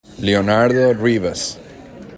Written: Leo Rivas

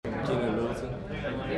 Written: Keynan Middleton

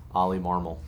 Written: Oliver Marmol

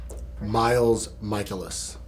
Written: Miles Mikolas

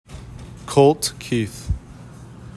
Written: Colt Keith

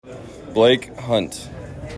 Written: Blake Hunt

